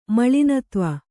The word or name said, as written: ಮಲಿನತ್ವ